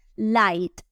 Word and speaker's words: light: Light también termina en GHT, pero uno solo pronuncia la T.